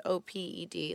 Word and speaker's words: op-ed: Is it when it says OPED? Like, how do you pronounce that? I've always wondered. – Op-ed. Yeah.